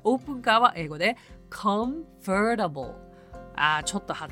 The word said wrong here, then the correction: convertible: 発音難しいですね convertible convertible car となります。